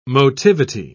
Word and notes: motivity: British English

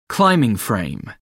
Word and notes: climbing-frame: American English

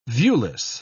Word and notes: viewless: British English

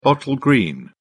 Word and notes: bottle green: British English

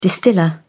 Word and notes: distiller: British English

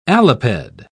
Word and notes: aliped: British English

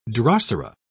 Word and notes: drosera: British English

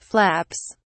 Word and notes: flaps: British English

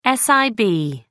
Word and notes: sib: American English

